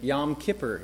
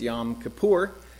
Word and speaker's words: Yom Kippur: Always used to look at those words, Rosh Hashanah and Yom Kippur is how I pronounced it back in the day. It's not Yom Kippur, it's Yom Kippur.